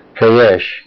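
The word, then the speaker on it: Kahash (כחש): Hosea 4:2 says, "By swearing and lying," the Hebrew word lying, kahash, K-A-H-A-S-H. K-A-C-H-A-S-H. Kahash is pronounced like Kaw, K-A-W, and K-H-A-S-H.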